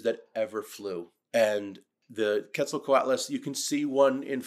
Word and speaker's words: Quetzalcoatlus: Quetzalcoatlus? – That's exactly how you say it.